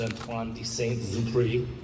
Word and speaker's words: Antoine de Saint-Exupéry: One of my favorite aviation authors, Antoine de Saint-Exupéry.